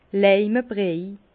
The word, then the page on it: Leime briej: Bas Rhin; Herrlisheim